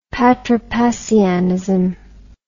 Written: patripassianism

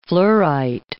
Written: fluorite

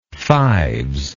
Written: fives

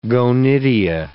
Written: gonidia

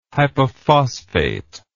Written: hypophosphate

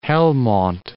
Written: Helmond

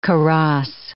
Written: kaross

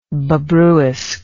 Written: Bobruisk

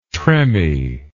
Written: tremie

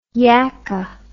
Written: yacka

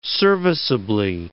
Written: serviceably

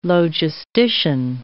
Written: logistician

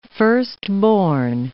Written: firstborn

